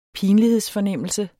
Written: pinlighedsfornemmelse

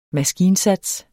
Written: maskinsats

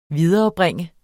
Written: viderebringe

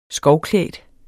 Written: skovklædt

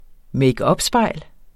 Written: makeupspejl

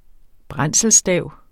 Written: brændselsstav

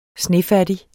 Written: snefattig

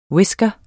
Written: whisker

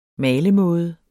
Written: malemåde

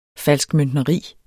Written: falskmøntneri

